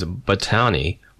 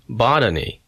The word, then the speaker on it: botany: for so-and-so's botany with the accent on the second syllable.